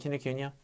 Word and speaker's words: Kinokuniya: And I've been trying to exhibit at the bookstore, at Town Hall, Kinokuniya, however you want to pronounce it. I pronounce it Kinokuniya.